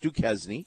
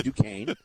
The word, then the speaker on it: Duquesne: Number one, it's not pronounced Duquesne, it's Duquesne.